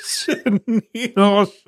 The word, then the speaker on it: Chanel: Chenille. – Hey, I was close. – Yeah. Chanel. – I knew it started with a sh.